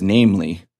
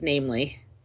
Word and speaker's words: NAMLE: which we'll refer to as NAMLE on the show. That's how you pronounce it, right? – Yes, it is NAMLE.